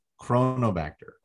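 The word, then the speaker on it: Cronobacter: So we talk about the Coronobacter. I got corona. Yeah. – You're putting an extra syllable. Coronobacter. – Coronobacter. Like it's a crown, not a halo.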